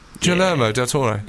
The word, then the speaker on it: Guillermo del Toro: Guillermo del Toro for The Shape of Water.